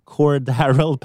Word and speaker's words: Cordarrelle: eh, det som är positivt då, det är att Cordell, Cordarrell Patterson, vi är där igen med uttal, svårt att säga, Cordarrell Patterson.